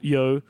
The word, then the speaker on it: Yeoh: Yeoh is a mother.